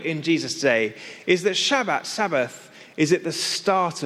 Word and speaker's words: Shabbat: Now, in Hebrew, the word is pronounced Shabbat.